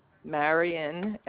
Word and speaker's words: Marian: Marion Schurz. – Hold on 1 second, please. You say your name was Marion? – Marion, M-A-R-I-A-N. Last name Schurz, S-C-H-U-R-Z.